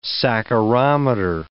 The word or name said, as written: saccharometer